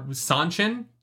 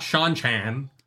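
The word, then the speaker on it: Seanchan: They are Sanchin, not Sean-chan.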